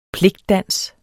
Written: pligtdans

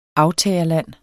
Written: AFTAGERLANDE

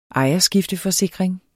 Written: ejerskifteforsikring

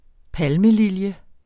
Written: yucca

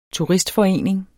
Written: TURISTFORENING

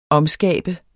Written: omskabe

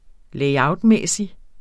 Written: layout

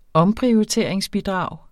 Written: omprioriteringsbidrag

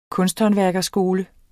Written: kunsthåndværkerskole

